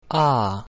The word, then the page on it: A: Latin